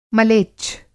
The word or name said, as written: मलेच्छ